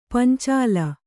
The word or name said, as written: ಪಂಚಾಲ